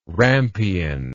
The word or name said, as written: rampion